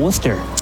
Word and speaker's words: Worcester: I hope all the Northeasterners tuning in appreciate the pronunciation of Worcester.